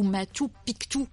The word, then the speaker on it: Machu Picchu: On pense au Machu Picchu ou Machu Picchu pour prononcer correctement en quechua.